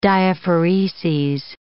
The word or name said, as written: diaphoreses